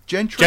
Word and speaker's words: Gentry: But a center role Gentry? – Gentry, you said it right, mate. First time. Don't say Gentry.